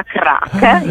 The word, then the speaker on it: craic: Da noi ha un altro significato, però si scrive craic, ma si pronuncia crack in irlandese, che definisce un momento meraviglioso, e sono i momenti meravigliosi che stiamo vivendo a questo Tocatì.